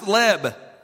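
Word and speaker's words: leb (לב): The word heart here in the passage in the Hebrew is pronounced leb, L-E-B.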